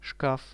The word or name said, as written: shkaf